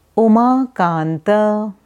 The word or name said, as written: Umakanta